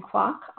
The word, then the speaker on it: Kwok: And my Twitter is at Christine Kwok, all one word.